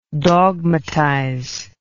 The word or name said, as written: dogmatizer